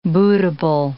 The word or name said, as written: bootable